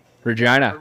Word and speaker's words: Regina: Regina. – Regina. – Yeah. – Yeah. Oh, that's right. It rhymes with, yeah. – Yeah, the city that rhymes with fun, yeah.